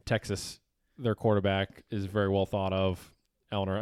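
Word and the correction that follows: Ehlinger: I mean, Am I saying that right? Ellinger? – Yes. Okay, Sam Ellinger.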